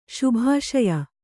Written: ಶುಭಾಶಯ

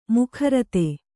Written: ಮುಖರಿತ

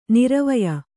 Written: ನೆರವೀಯು

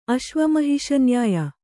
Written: ಅಶ್ವಮಹಿಷನ್ಯಾಯ

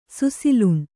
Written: ಸುಸಿಲುಣ್

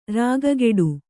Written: ರಕ್ಕೆಗಟ್ಟು

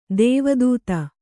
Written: ದೇವದತ್ತ

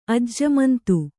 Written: ಅಜ್ಜಮಂತು

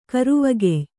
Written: ಕರುವಗೆಯ್

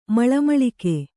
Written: ಮಳಮಳಿಕೆ

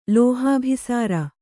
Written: ಲೋಹಾಭಿಸಾರ

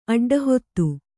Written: ಅಡ್ಡಹಾದಿ

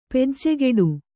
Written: ಪೂಜೆಗಟ್ಟು

